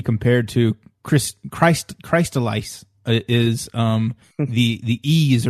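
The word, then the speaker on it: Ys: It's said E's, but it's spelled Y apostrophe S.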